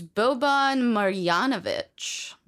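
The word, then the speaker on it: Boban Marjanović: His name is Boban Marjanovic.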